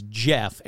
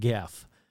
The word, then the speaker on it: Gef: It's spelled G-E-F. But it's kind of like, you know, GIF. Is it GIF or is it JIF? So I'm just going to say it's Jeff and not Geff. So anyhow, it says, yeah, my name's Jeff.